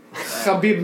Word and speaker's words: Khabib: You're not saying it right. – Khabib. – Khabib. – That's closer, but it's Khabib. – Khabib. Just Khabib. Say Khabib, because you can't do it right.